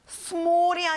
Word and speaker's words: small: small이 아니라 입을 밑으로 벌려서 small. 잘했어요.